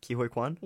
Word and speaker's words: Ke Huy Quan: Ke Huy Quan.